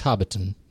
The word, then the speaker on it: Hobbiton: It's Hobbiton, really. You just say it like that. – Oh, okay, Hobbiton.